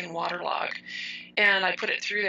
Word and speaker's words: Waterlogue: Another one I really like to use just to be artistic is Waterlog. It's spelled Waterlogue. Waterlog.